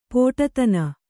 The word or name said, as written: ಪಡಿದನಿ